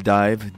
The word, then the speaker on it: DIIV: Ce soir, parenthèse envers un groupe qui est le projet de Zachary Cole, un groupe qui s'appelle Dive, D-I-I-V, avec plein de prononciations selon si vous êtes américain ou français qui essaye de parler de l'anglais comme moi. Dive, ils n'ont pas donné de nouvelles depuis 2016 et un deuxième album chez le label de Broken Capital Tracks.